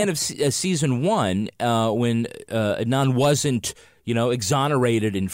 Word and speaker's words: Adnan: we've been mispronouncing Adnan Syed's name. We say Adnan, and it's really Adnan, and that's disrespectful to him.